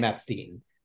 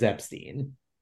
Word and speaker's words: Epstein: The family pronounces their name Epstein. So that's how I've been calling it, and that comes straight from Brian's mom. So that's how the family has said it over the years. That being said, you can find plenty of footage of folks like John Lennon saying Epstein, and Brian not correcting them. So you know, I don't think he was bothered by either. But the correct family pronunciation is Epstein, right? – Like Leonard Bernstein.